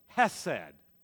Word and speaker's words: hesed: And it is a story of hesed, that wonderful Hebrew word that has rich and profound meanings. It's spelled, for those of you who are taking notes, it's spelled the English transliteration is H-E-S-E-D, hesed.